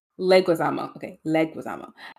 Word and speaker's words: Leguizamo: So actor John Leguizamo. Did I say it right this time? – Leguizamo, a vocal advocate for greater Latino representation in Hollywood, led the charge on Instagram.